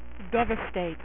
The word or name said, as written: devastator